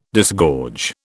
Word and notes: disgorge: British English

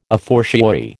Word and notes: a fortiori: American English

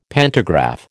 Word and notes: pantograph: American English

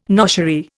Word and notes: noshery: Indian

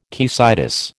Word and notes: caecitis: American English

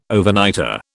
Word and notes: Overnighter: British English

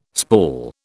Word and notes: spall: British English

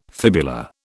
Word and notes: fibula: British English

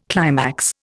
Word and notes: climax: Indian

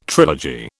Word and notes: trilogy: British English

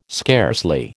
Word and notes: scarcely: American English

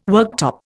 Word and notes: worktop: Indian

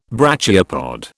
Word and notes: brachiopod: British English

